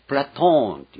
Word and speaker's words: プラトン: プラトーンっていうのが本来なんです。プラトンだ。